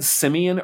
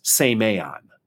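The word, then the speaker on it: semeion (σημεῖον): And you said Simeon earlier. I bet what she's picking up on is the Greek word is pronounced semeion.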